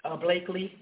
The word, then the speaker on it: Blakely: Blakely, that's how you say her name.